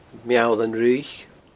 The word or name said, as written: Meall an Fhraoich